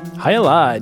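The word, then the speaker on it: Jai Alai: Hyalai, not to be confused with Miller High Life. – Not at all the same. – Very, very different. – If you can find the Cigar City Brewing Hyalai in a tap room near you or a grocery store, please, please check it out and thank me later.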